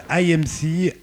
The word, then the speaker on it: AYMCE: Il s'appelle IMC, AYMCE.